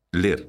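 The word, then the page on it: ler: Galician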